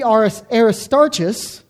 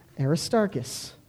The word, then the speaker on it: Aristarchus: As I was reminded and graciously informed by my friend, Mike Miller, a fellow Greek student, that there is no ch sound in the Greek. So it's not Tychicus, it's Tychicus. And it can't be Aristarchus, because again, there's no sound like that. It's Aristarchus.